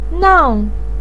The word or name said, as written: nang4